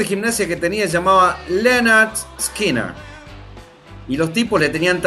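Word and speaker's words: Lynyrd Skynyrd: Tal es así que el primer disco se pronuncia Leonard Skinner.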